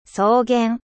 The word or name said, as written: 草原